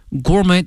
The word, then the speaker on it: gourmet: 它发生了一个演变哈，用这个词 evolve E-V-O-L-V-E 哈，这个词表示一个演化一个变化 from a primitive cooking form to sophisticated gourmet delicacy gourmet G-O-U-R-M-E-T 后面这个 T 是不发音的